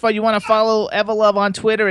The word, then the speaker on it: Evolove: You guys, Evolove, how do you pronounce it? Is it Evolove or Evolove? – Yes, it's Evolove. You have it correct.